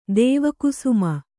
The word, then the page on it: ದೇವಕುಸುಮ: dēva kusuma